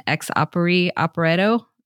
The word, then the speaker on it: ex opere operato: But Holy Week does not work, as it were," I'm going to totally butcher this Latin, "ex opere operato." Am I saying that right, sister? Mm-hmm.